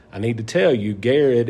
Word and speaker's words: Garrard: R R A R D which looks like Gerard, um, but it's pronounced Garrett.